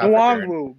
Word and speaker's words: Nwangwu: But, like, you know, that was, you know, considering was Ngangwu, he got hurt off the first kickoff. – Nwangwu, bro. – Nwangwu.